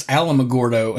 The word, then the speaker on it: Alamogordo: And I'm like, that's not how it's said. It's Alamogordo.